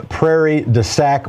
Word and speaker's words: Prairie du Sac: A different Mark from Prairie du Sac, Wisconsin. I hope I'm pronouncing that correctly. Go ahead, Mark. – You are.